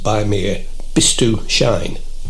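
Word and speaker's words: Bei mir bist du schön: And in Yiddish, it's pronounced "By mir bist du schön." Different. A bit like Bayerisch, Bavarian. – I mean, it's, in German, it would be "By mir bist du schön," wouldn't it?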